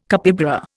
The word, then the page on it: capybara: Indian